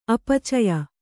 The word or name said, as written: ಅಪಚಯ